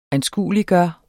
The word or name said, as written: ANSKUELIGGJORT